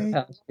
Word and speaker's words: Deripaska: I think it's Deripaska. Is that right? – Deripaska. Yeah. Deripaska, yeah. So he became a, this is after he left the FBI, he became a, like an agent for Deripaska.